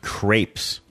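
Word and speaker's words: crêpes: Crepes. In French, it rhymes with steps, but here everyone says it as if it rhymes with apes.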